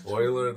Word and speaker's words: Euler: For those of you listening, Euler is spelled E-U-L-E-R. – Right. He was a man. It'd be great if he was like An oil man. – An oil man. – Oiler the Oiler.